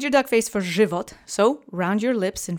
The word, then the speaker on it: żywot: So now that you have that fresh on your tongue, let's say żywot three times together. Accent is on the first syllable. Żywot.